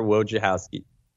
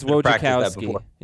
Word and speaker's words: Wojciechowski: we were informed that Asher Wojciechowski. Saying that right? – I think it's Wojciechowski.